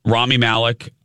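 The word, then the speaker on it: Rami Malek: uh, Rami Malik, Rami Malik. I heard. I thought. I think that's actually how you say it. I heard him say his own name.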